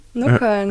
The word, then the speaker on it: Nockerl: Außerdem machen sie die besten Salzburger, wie spricht man es aus, Nockerl? – Nockerl.